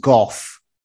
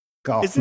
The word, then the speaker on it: Gough: It's pronounced Goff. – Oh, is it Goff? – Goff, Michael Goff, yeah.